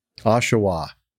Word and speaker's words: Oshawa: Lieutenant from Southwest Ontario was carried to Oshawa from our base in Tillsonburg. Oshawa. I should have known better. Thank you, Liz. – Emphasis on the wrong syllable.